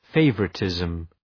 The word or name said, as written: favouritism